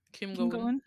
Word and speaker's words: Kim Go-eun: We have Kim Go-eun. Is that how you say her name? – Kim Go-eun. Yes.